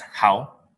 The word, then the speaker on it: Hau: My Vietnamese name is Hau, H-A-U.